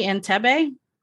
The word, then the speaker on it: Entebbe: Entebbe? – Yes.